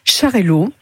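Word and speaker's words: Xarel·lo: Alors, nous avons ramené Charello. Tout catalan sait que le X se prononce CH, donc cette cuvée, nous avons choisi de l'appeler du nom de son cépage principal, qui est un cépage catalan.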